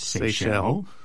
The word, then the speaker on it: Seychelles: The most frequently mispronounced country names, the ones nobody got, are the Seychelles, which actually is pronounced Seychelles, and Brunei, which is actually pronounced Brunei.